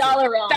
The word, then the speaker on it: Dalaran: But chapter 3 is Streets of Dalaran, and then chapter 4 how do you pronounce Dalaran.